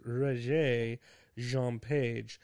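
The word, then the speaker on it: Regé-Jean Page: I said his name wrong earlier. I think it's Roger Jean Page.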